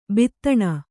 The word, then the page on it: ಭೇದನ: bhēdana